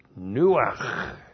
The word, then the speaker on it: nuach: And then we have the verb nuach. N, long U, A, C, H. Nuach.